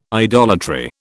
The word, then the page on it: idolatry: British English